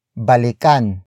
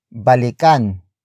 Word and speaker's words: balikan: And that action is balikan.